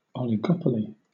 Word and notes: oligopoly: British English

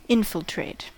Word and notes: infiltrate: American English

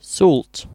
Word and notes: salt: British English

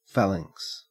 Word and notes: phalanx: Australian English

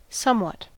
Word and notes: somewhat: American English

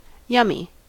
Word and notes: yummy: American English

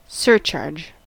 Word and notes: surcharge: American English

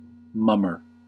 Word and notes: mummer: American English

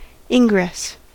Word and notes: ingress: American English

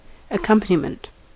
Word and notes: accompaniment: American English

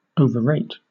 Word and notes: overrate: British English

